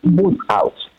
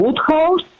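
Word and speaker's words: Wodehouse: El autor es Pelham Grenby Woodhouse, obviamente inglés, y está editado por Anagrama.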